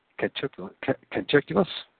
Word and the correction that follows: Catullus: Is that how you pronounce that again, Keith? – Catullus. – Catullus, thank you.